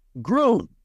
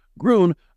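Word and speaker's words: Grön: So with that, I'm here with the founder and CEO of Groon, but you might see it spelled G-R-O-N, but that is the way it's pronounced. Groon, I'm here with Christine Smith.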